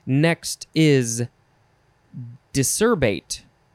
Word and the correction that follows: decerebrate: There's an R in there. Decerebrate. There's an extra syllable.